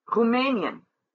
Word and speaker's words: Rumänien: Willkommen, Rumänien have 3 syllables, and the middle syllable is accentuated.